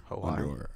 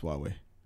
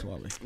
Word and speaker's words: Huawei: Huawei is under Hawaii. – It's Huawei. – It's Huawei? – Yeah. – That's how you pronounce it? – Yeah, it's Huawei. – Nah, bro. – Yeah, it's Huawei. – No. – But remember, I was pronouncing it wrong an entire episode. It's Huawei. – I thought Huawei was the wrong pronunciation. – I was saying Hawaii as well.